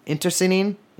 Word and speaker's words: internecine: I mean, there was a ton, and they engaged in both like intercening. I do not think I pronounced that correctly. The kind of warfare where you kill your friends too. Internecine. Damn, first time saying that and not reading.